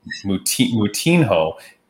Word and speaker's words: Moutinho: And so, Prince, I swear you butchered that name. I think it's Moutinho.